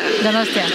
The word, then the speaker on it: Donostia: Donostia. – Donostia. – Donostia.